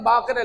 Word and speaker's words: باقر: امام باقر علیہ السلام، اکثر ہماری یہ کیا ہے، لفظ باقر کو باقر کہا جاتا ہے، باقر ادھر ہو، یہ غلط ہے. باقر اصل تلفظ ہے، تلفظ کیا ہے؟ باقر فاعل کے وزن پر ہے. ناصر، ناصر کہیں آپ مثال کے طور پر، صادق کو صادق کہیں غلط ہو جائے گا.